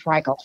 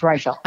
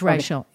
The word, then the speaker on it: Reichl: Well, first of all, I'm glad you know how to pronounce her name because I've always called her Ruth Reichl. – I know. – So it's Reichl. – I know. Reichl. It's Reichl.